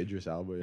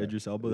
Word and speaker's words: Idris Elba: Is that even how you pronounce his name? – No idea. Yeah, no, definitely Idris Elba. Yeah. – Idris Elba. That sounds right.